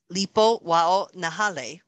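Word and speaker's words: Lipo-wao-nahele: Part two is Lipo Wao Nahale, which I hope I'm saying that correctly. – That sounded pretty good, Hawaiian, actually.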